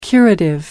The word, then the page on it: curative: American English